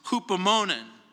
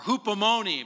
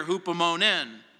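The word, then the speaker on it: hupomonen (ὑπομονήν): The Greek word here is one of my favorites that rhymes with Italian ice cream, spumoni. It's hupomonen, or hupomoni, but here hupomonen.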